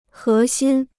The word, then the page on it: 核心: hé xīn